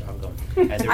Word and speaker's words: Shawangunk: I'm going to move it, but also just for the purposes of the town of Shawangunk, it is pronounced Shawangunk.